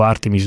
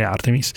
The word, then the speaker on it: Artemis: E in generale l'architettura Artemis, io mi leggo alla pronuncia americana perché vivo a Houston, sono abituato a pronunciarla in questo modo, dico Artemis non perché lo preferisca, solo perché sono abituato a sentirlo pronunciare Artemis.